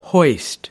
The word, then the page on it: hoist: American English